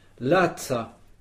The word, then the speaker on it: laca: La, L-A-S en français. Mm. Donc lazza, ça s'écrit L-A-C-A, puisque n'oublions pas que le C se prononce ts.